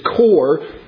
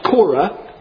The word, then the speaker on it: Korah: Now, it's spelt C-O-R-E here in English. And so, based on the general rules of English that are never consistent, we probably read it as Kor. But in Greek and in Hebrew, it's pronounced Korah.